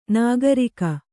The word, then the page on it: ನಾಗರೀಕ: kannada